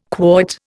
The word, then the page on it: quoit: Indian